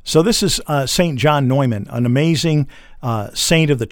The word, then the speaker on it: Neumann: Now, we pronounce his name St. John Neumann many times, which is the traditional pronunciation from where he was in Bohemia, which is now the Czech Republic.